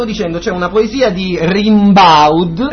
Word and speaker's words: Rimbaud: Allora si pronuncia Rimbaud. Arthur Rimbaud.